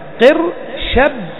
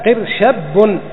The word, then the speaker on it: قرشب: كقرشب، تقول كقرشب كاف للتشبيه ذا، لكن انطق الكلمة قر حرفان شب الشين الأولى الشين مفتوحة والباء ساكنة ثم الباء الأخيرة متحركة، قر شب والثانية الباء الثانية قر شب.